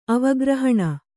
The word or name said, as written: ಅವಗ್ರಹಣ